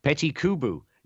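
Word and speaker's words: Petit Qoobo: I don't know how you pronounce this furry robot, the Petit Kubu. – Petit Kubu. Yeah.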